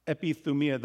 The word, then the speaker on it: epithumia: epithumia, the Y is pronounced like a U, epithumia, desire or craving.